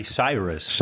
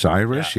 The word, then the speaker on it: Cyrus: En eh, is onder andere gefinancierd door Miley Cyrus, zeg ik dat goed? Of Cyrus, ik weet niet. Miley Cyrus. – Cyrus, ja.